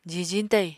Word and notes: jijiñ tayiñ: Apatani

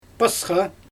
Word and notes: pasxa: Xyzyl

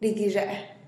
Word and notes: rigizhe'e: TdVZ

